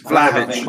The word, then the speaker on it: Vlahovic: Vlahovic.